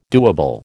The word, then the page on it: doable: American English